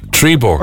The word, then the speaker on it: Trebor: So I never knew this, but the brand of mint that's spelled Robert backwards is Trevor. – Trebor. – Or Trebor. – Yeah, Trebor.